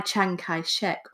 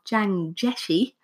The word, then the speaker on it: Chiang Jieshi: So the Kuomintang, the Chinese nationalists led by Chiang Kai-shek or Chiang Jieshi,